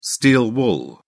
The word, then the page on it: steel wool: British English